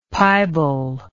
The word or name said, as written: pibal